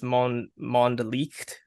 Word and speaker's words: Mondlicht: Yes, my third pick is, my German is rusty, but I believe it's pronounced Mondlicht.